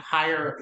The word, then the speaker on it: Hyer: So when we speak of voids in the market, my foundation, the Higher Calling Foundation, and Higher is spelled H-Y-E-R because it's my last name. It's a play on words.